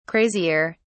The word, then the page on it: crazier: British English